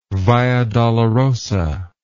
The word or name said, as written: via dolorosa